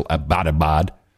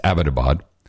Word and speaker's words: Abbottabad: not a single network pronounces properly. They all stay still Abbottabad. It's Abbottabad.